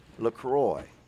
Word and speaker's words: LaCroix: I don't mean to correct you both, but my ancestors are from the French Caribbean, as you know, and I think it's LaCroix, the cross. – You know, I said that, and then someone corrected me and told me it's from a place where they pronounce it LaCroix.